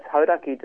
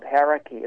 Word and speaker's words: Hauraki: And for me, you know, start maybe, as we say, it's Hauraki District Council, not Haraki, as an yes.